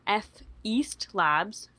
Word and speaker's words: Feast Labs: He ends up in a F East Labs, Feast Labs, I guess if you say it the way it's spelled, but there's different capitalization.